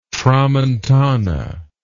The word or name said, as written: tramontana